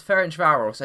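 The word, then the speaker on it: Ferencváros: I'm pretty sure you just tried to pronounce Ferencvaros and you completely, absolutely messed it up.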